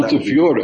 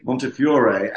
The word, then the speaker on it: Montefiore: And secondly, I think you'll appreciate that there are many pronunciations of that name. I mean, if you wanted to do the Arabic and Italian version, mm-hmm. I'm sure you could guess what Montefiore. – That would be. Montefiore, and try the Arab version of Sebag.